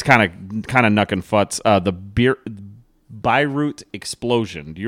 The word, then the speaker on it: Beirut: I think it's Beirut. – Beirut.